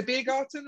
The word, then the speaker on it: Biergarten: I'm sure I got it wrong, but it's the Biergarten, right? Is that how we say it? – Yes, that's perfect.